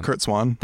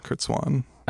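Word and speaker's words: Curt Swan: And isn't that called Whatever Happened to Kurt Swain? – Kurt Swan. – That's how you pronounce it, but that's how you spell it, Swain? – Oh, I thought it was just S-W-A-N, Kurt Swan.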